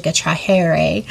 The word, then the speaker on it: trahere: Well, it's spelled T-R-A-H-E-R-E. So I'll pronounce it in Spanish, a trajere, drawing back. So re being back and traje being draw or to drag, which sometimes I think we have to be drug back.